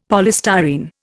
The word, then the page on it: polystyrene: Indian